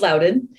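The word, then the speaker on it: Loudoun: It's pronounced Loudon.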